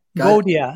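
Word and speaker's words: Gaudiya: So what would I mean, it, you know, in your book, you're speaking a lot to the Gadia community, right? I don't know if I pronounced that right. Is that the right pronunciation, Swami? – Which words you use, sorry? – Gadia? – Gaudia. Yeah, Gaudia.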